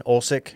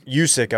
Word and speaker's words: Usyk: So you've got Anthony Joshua, who fucked everything up by losing to the Ukrainian, Usyk. – Yeah. – Usyk, excuse me. – I've always wondered how to say this man's name. – Usyk, I believe.